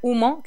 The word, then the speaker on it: Ummo: Sí, es el 27 de enero acá en Santiago, en Bar Humo, que se escribe UMMO, y ¿Ya?